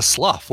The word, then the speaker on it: Slough: It was Slough, wasn't it? S-L-O-U-G-H? – Yeah, I think it was Slough, right?